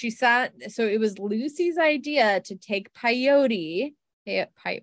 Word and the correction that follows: peyote: I've never heard that term rhyme with coyote. – That's what I was saying. – Coyote. – I said it like coyote. Well, that's embarrassing. So they took peyote.